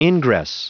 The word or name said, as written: ingress